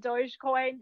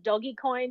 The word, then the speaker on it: Dogecoin: So most people will pronounce it Dogecoin. There is a contingent that pronounces it Doggycoin because it's originally based on the meme that was like a misspelling of the word dog.